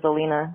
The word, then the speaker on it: Alina: It's Alina. I've been thinking about you. – Click. – Yeah, I mean, that's true. My name is Alina, rhymes with Marina.